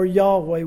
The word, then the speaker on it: Yahweh: He is Jehovah, or Yahweh, which is probably the way it's pronounced in the Hebrew.